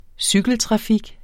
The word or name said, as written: cykeltrafik